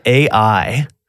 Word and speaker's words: Ai: And it's called AI. – Yes, not artificial intelligence. It wasn't Terminator. They weren't fighting robots. But you say it just like it's spelled, evidently.